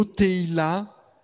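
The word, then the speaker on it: Tehila (תהילה): Il y a un autre mot en hébreu, qui existe pour désigner la louange, et ce mot est Tehila. Donc je l'ai bien prononcé. Le mot Tehila,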